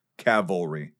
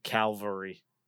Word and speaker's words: cavalry: So cavalry. – Calvary. – Calvary was the hill that Jesus was crucified on.